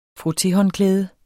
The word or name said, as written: frotté